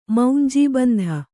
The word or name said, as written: ಮೌಂಜೀಬಂಧ